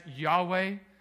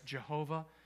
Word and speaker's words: Yahweh: And it is sometimes pronounced in our English sort of translation of this as Yahweh. Sometimes decades ago it was translated or pronounced as Jehovah.